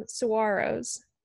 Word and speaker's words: Saguaro: It's so great, especially being across the country where we have, they're cacti, but they call them saguaros.